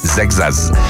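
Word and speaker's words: Seixas: Paul Sextas. Ja, je kan het uitspreken zoals je wil, maar zo moet je het doen. Sextas. De wielrenner, hij gaat zijn debuut maken.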